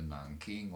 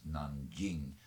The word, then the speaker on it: Nanjing: Men nu vet jag, Marcus Neff, att du är engagerad i inte så långt utanför den gamla huvudstaden, den jag kallar för Nanking och som uttalas Nanjing, i floden, den långa, långa, längsta floden Yangtze.